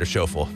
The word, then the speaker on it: Schauffele: Schauffele, right? – Schauffele, yeah. I don't know him. – I've just occasionally watched golf, okay. And so I've heard that's how it's pronounced.